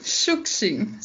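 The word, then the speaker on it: Shukshin (Шукшин): E uma outra questão simples: minha pronúncia está correta? Shukshin. – Uhum, Shukshin, certinho.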